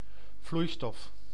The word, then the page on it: vloeistof: /ˈvlui̯stɔf/; [ˈflui̯stɔf]